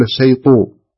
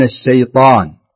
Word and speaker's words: الشيطان: مفتوحة بس لا تكز فيها الشفه عند النطق، لا تكز الشفه حتى لا يتبادر للسامع إن كأنك تقول الشيطو، فلا تقول الشيطو تضم الشفتين، لا افتح الشفتين مرة من الشيطان من الشيطاء.